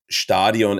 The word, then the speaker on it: Stadion: Then we have the Allianz Stadium Stadion, as it is pronounced in German, in Vienna, where Rapid Vienna plays in.